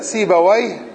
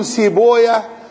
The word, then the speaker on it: سيبويه: هو إسحاق بن راهويه وكلمة راهويه وسيبويه ونفطويه تلفظ لفظين لفظ أهل اللغة هكذا سيبويه نفطويه مهرويه ولفظ أهل الحديث يقولون سيبويا نفطويا راهويا وكلاهما جائز والألفاظ الأعجمية يتوسع فيها نعم